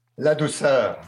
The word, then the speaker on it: Ladouceur: La douceur. – Yes. La douceur. I was going to say, like. – He, he wrote me a note saying, you pronounce it la douceur.